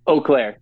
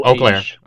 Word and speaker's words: Eau Claire: So I've never been to Boo or Claire. – Did I pronounce that right? Eau Claire, like O. – Eau Claire. Okay. Oh yeah, yeah. It's like French.